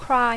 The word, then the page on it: cry: English